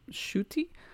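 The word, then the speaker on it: Ncuti: Oh my god, I believe you pronounce his name Shuti.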